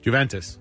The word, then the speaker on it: Juventus: Juventus.